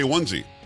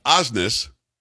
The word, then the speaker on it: Osnes: I'm telling Kara, I want to say onesie. – I don't think you should, though. – It's Osnes. Laura Osnes.